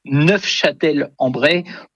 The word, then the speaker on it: Neufchâtel-en-Bray: Et en effet, pour les puristes, dont je ne suis pas, puisque je n'ai pas prononcé le F, mais pour les puristes, en effet, il faut prononcer Neufchâtel-en-Bray pour, en effet, peut-être se différencier de la ville de nos amis suisses.